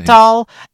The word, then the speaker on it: Abiatal: But we will see if Avellino, I can't even remember how you pronounce his first name. – Abiatal Avellino.